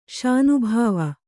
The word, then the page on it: ಶಾನಬಾವ: śanabāva